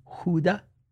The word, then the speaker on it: Gouda: I like a gouda. I like... – Gouda's actually pronounced huda.